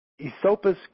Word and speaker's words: Esopus: Even people who grew up near it sometimes mispronounce it, but it's Esopus Creek in the Catskill.